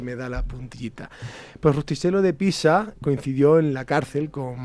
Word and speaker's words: Rustichello de Pisa: Así es, no es otro que Rustichello de Pisa. Perdóname, Vini, si lo he pronunciado mal. – Ha ido muy bien, ¿eh? Con, un, tono muy chulo. Muy ¿eh? Rustichello de Pisa.